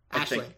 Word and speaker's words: Aisling: Aisling. It's pronounced Aisling.